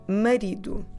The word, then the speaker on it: marido: it's the word marido. O marido. The husband. O marido. Let me spell it for you. I'm Portuguese. M-A-R-I-D-O. Marido.